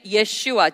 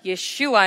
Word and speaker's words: Yeshua: But what this man named Yeshua, Jesus in English, but Yeshua in the original name, the pronunciation,